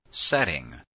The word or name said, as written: setting